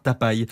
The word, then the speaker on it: Tapy: Alors pour tenter d'exister aux côtés de Johnny Hallyday, Eddie Mitchell, Eddie Creever, c'est bien, il avait demandé à ce qu'on prononce son nom à l'américaine. Il se faisait appeler Bernard Tapie. – Non. – Si, écrit T-A-P-Y sur les pochettes de ses 45 tours, aujourd'hui collector.